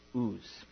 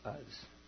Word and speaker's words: Uz: And the scholar pronounced it Uz, not Uz.